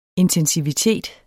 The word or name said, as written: intensivitet